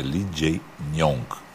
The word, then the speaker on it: Lee Jae-myung: E a gente aprendeu, eu pronunciei errado, é Lee Jae-myung.